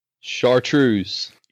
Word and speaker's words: chartreuse: Chartreuse. – Yeah, I hope I said that right. Someone might correct me. – You did.